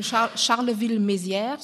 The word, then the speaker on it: Charleville-Mézières: So, und die Stadt, neben der wir wohnen, unser Wohnort ist neben Charleville-Mézières, so spricht man das aus.